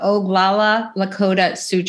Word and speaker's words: Oglala Lakota Sioux: You were born and please forgive me if I say it wrong, the Oglala Lakota Sioux Tribe.